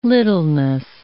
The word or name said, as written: littleness